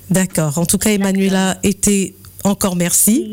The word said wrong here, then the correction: Manuela: Oui, c'est Manuela. – D'accord, Manuela a été.